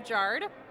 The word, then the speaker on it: Giard: My name's Melissa Giard. – And could you spell that, please? – G-I-A-R-D.